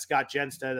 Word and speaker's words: Jenstad: I am at Scott Jenstad.